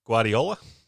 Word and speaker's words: Guardiola: I'm going to butcher his last name, Guardo, is it? – Guardiola. – Guardiola.